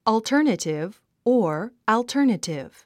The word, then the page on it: alternative: ɔːltə’ː r nətiv; オゥタネティブ